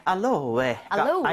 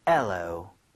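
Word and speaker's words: aloe: 알로에가 아니라 발음은 엘로입니다. – 뭐요? – N은 아예 발음이 없고요. 엘로.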